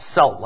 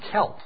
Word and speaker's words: Celt: You might say think it's pronounced Celt, like the basketball team in Boston, the Boston Celtics. But actually, it's pronounced Celt. C-E-L-T.